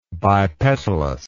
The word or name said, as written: bipetalous